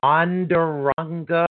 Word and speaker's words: Onondaga: And let's see, she was born in Onondaga. Oh my God, Onadoga. Never heard of it. Bruce, New York. – Onadaga, New York. Okay. – Oh God, did I spell that? See, I had to switch glass to the one that I could see on the way up. – I don't think it's Onadaga isn't quite right.